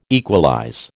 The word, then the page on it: equalize: American English